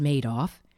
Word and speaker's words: Madoff: But Madoff, yes, pronounced Madoff, wasn't just successful.